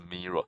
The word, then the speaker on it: Miro: 对，他们比较常用的做法就是用一个叫 Miro 还是 Miro 的工具哦，应该他们的发音是 Miro，是 M-I-R-O。